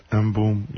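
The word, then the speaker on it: M'Boom: B-O-O-M. – That's some people pronounce it Boom, others M Boom, you know, so.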